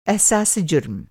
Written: एहसासे जुर्म

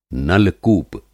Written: नल-कूप

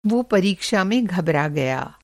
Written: घबराना

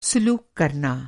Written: सुलूक

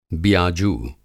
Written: बियाजू

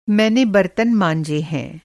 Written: मँजना

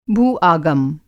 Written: भूआगम

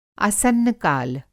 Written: आसन्नकाल

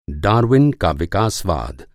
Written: विकासवाद

